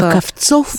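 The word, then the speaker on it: Коковцов: Почему именно Владимир Николаевич Каковцев? – Если позволите, я сразу поправлю фамилию со спорным ударением, потому что... – Каковцов? – Да. Его предки, его, ну, скажем так, деды, прадеды, возможно, были Каковцевы.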